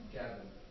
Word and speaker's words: Gavin: Gavin. – Could you spell it? – G-A-V-I-N.